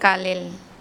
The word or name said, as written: kalel